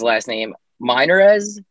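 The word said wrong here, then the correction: Meinerz: This is Miners. A center. Miners. Sorry.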